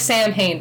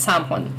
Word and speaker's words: Samhain: The original name was Samhain, which is S-A-M-H-A-I-N, and you don't pronounce it like it's written in English lettering. Yeah, it looks like Samhain, but it's Samhain.